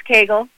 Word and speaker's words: Cagle: Yep, it's Cagle.